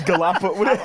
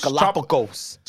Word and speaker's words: Galapagos: All I got is I know that's not how you say it. Yeah. It's Galapagos. – How you supposed to say it? Galapagos? – Galapagos.